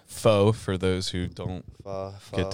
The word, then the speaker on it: pho: Yeah, pho you, dude. – I say pho because it looks P-H-O.